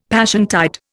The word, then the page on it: Passiontide: Indian